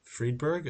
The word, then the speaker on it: Friedberg: So we've pumped up David Friedberg. Am I saying that right? – Yes.